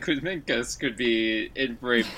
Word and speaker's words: Kuzminskas: We need to learn how to pronounce his name by next year. That's the real trick. – Definitely. – Daukis Kuzminskas. – Oh, there you, all right. We have a European on the podcast. That's where it will be our official pronunciation guide for him.